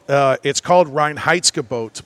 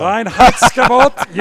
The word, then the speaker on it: Reinheitsgebot: It's called Reinheitsgebot. – Reinheitsgebot. – My chef likes to refer to it as Ron Howard's boat. – Big deal. – Reinheitsgebot. You will not pronounce it incorrectly. Reinheitsgebot.